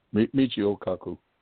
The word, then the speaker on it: Michio Kaku: Michio Kaku, yeah.